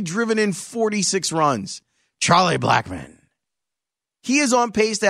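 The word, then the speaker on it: Blackmon: Look at what Charlie Blackman is doing for Colorado. Wait, I didn't say it right. Charlie Blackman.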